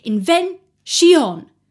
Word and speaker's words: invention: The second line, for example, invention. The ending is pronounced with two syllables because it sounds better.